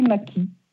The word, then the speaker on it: Madchi: Mathie, ça s'écrit, c'est un nom d'origine italienne. – Oui. – M-A-D-C-H-I. – D'accord. Et ça se prononce Mathie. – Ma belle famille a décidé que ça se prononçait Mathie.